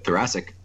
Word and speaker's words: thoracic: Is that how you say it? Thoracic? – Right? Yep, thoracic.